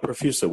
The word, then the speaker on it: Profusa: I have Ben Huang, CEO of Profusa, P-R-O-F-U-S-A.